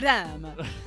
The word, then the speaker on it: Saitama: From our good friend Sayatama. I think I added a syllable in there. Anyway, cool. – Sayatama. With you, with the boy of the kelp to get the hair and you say Saitama.